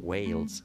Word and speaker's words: Wales: آنچه ما میگوییم که بازم ناچارم باز تلفظ بکنم خیلی خوش آمده از تلفظ این ولز ولی آنچه آنان میگویند البته با تلفظ آمریکای شمالی یعنی کسایی که انگلیسی زبان هستن و اهل ایالات متحده و کانادا هستن این رو به این شکل تلفظ میکنن ویلز ویلز ویلز آنچه میتوان گفت ویلز